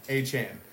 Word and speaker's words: Achane: And that's how you say it, I just learned. Achane.